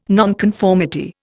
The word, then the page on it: nonconformity: Indian